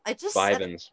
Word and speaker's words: Vyvanse: Vyvanse? – How do you say it? Vyvanse? – Vyvanse.